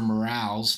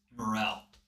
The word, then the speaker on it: morel: They're called morales. It's like they're spelt like morals. – So well, it is spelled like morals or no? – The pronunciation. – Pronunciation is more morales. – No, it's morel.